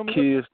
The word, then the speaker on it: Zacchaeus: Kairos the Great. I want to make sure I'm saying that right, though. Welcome to the broadcast. – Zacchaeus the Great.